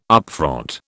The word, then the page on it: upfront: British English